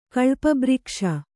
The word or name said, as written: ಕಳ್ಪಬ್ರಿಕ್ಷ